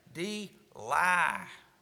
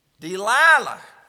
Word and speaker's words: Delilah: The Bible said in verse number 6, and Delilah, now here's how you say her name, Delilah. What do you expect for somebody the middle of their name is lie? Delilah.